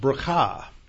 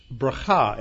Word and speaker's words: bracha: And the Hebrew is pronounced bracha. B-E-R-A-K-A-H would be the correct spelling because it doesn't have a C-H or a chet in there.